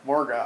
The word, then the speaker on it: Morgoth: It's not even that hard. – Morgoth. – It's two syllables.